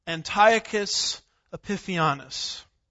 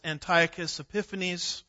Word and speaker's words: Antiochus Epiphanes: Well, about 165 BC, that would be 165 years before Jesus showed up, there was a man named Antiochus Epiphanes. Sometimes it's pronounced Antiochus Epiphanes. It just depends on which syllable gets the emphasis, as the saying goes.